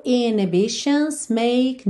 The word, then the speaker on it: inhibitions: Ela é uma palavra que tem uma letra H, que pode ser pronunciada ou não. Então, a gente tanto tem inhibitions com H, inhi, inhi tem esse H, H, ok? Inhibitions quanto inhibitions. Aqui na música a gente não ouve o H, então a gente vai ter no inhibitions make no conditions.